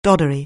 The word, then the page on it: doddery: anglais